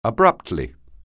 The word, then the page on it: abruptly: anglais